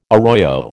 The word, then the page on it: arroyo: American English